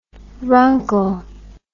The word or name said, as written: runkle